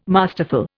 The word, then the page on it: masterful: Indian